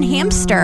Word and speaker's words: hamster: No, there's no P in hamster, and I've been saying it wrong my entire life.